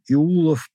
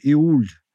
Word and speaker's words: Иул: В родственной северогерманской мифологии божеству солнца был посвящен Иулов праздник или Иул, допустимо произношение Иуль,